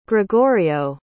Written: gregorio

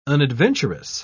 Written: unadventurous

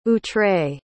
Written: outré

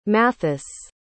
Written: mathis

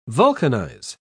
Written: vulcanize

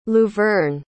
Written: luverne

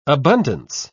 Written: abundance

